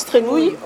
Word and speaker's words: Trémoille: Ça s'écrit Trémoille, mais ça se prononce Trémouille, qui ont été marquis de Noirmoutier.